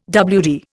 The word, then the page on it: WD: Indian